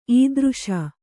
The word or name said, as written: ಇದಿರೀಸು